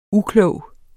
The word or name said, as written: UKLOGE